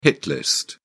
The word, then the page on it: hit list: British English